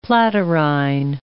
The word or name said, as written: platyrrhine